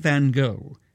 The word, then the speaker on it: Van Gogh: One of those enigmas is a Dutchman known as Vincent van Gogh, Van Gogh, if you pronounce it like the Dutch would, whose magnificent paintings hang in a museum that bears his name in Amsterdam.